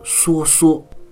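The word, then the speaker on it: 肅肅: 通常在很多现在《诗经》的读本里啊，素素被解释为细密整齐的样子，也被读作缩缩。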